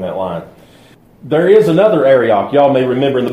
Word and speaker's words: Arioch: Now, the next person, Arioch, if I could pronounce his name in Hebrew like the experts can, it's like Arioch. It's something like that. It's like you're clearing your throat.